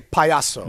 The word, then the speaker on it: payaso: You would say payaso. – There it is. There's my guy right there. – You don't know how I know that, LaVar? – Because basically Jonas calls me a payaso 4 times a week.